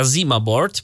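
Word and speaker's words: ZimaBoard: Coś, co brzmi bardzo swojsko, jak sobie to przeczytamy syntezą, bo nam to przeczyta Zima Board. Zwłaszcza ta zima, która coraz bliżej, ale to zima, z tego co wiem się wymawia, Zima Board.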